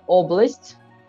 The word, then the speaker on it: oblast: Uh, outra para, palavra que a gente também mencionou hoje é oblast, né? sílaba tônica no O, oblast.